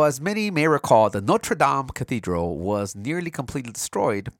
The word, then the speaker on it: Notre Dame: It's pronounced Notre Dame. Notre Dame. Come on, it's not a university in, in uh, Notre uh, Indiana.